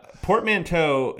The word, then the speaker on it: portmanteau: Portmanteau is spelled with an E, and the last syllable reminds with toe, not two.